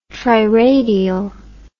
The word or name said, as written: triradial